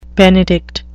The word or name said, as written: benedict